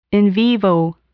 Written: in vivo

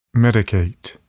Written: medicate

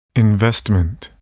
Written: investment

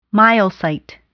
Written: myocyte

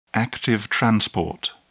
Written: active transport

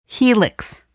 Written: helix

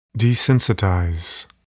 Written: desensitize

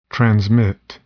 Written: transmit

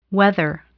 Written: whether

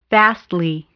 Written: vastly

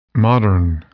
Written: modern

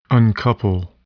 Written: uncouple

